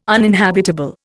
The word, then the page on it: uninhabitable: Indian